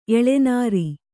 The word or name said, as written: ಎಳೆನೀರು